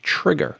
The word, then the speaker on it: Trygger: And this case, it's called the Trigger. And it's a camera case for the iPhone, which Trigger is spelled T-R-Y-G-G-E-R. That's T-R-Y-G-G-E-R.